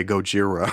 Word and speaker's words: Gojira: And I look it up and they're like, for the original Japanese pronunciation of Godzilla. And I laughed for a solid hour every time I would say Gojira.